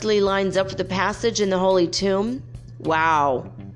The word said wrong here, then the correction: tome: And by tomb, I mean tome, T-O-M-E. Sorry about that. Said that wrong.